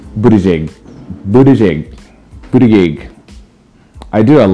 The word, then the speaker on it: Buttigieg: Somebody called him butt tag. I'm like, are you kidding me? What is wrong with you? Like, get it together. Learn how to pronounce these names.